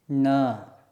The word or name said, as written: ṇ